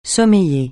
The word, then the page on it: sommeiller: francais